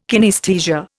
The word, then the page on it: kinaesthesia: Indian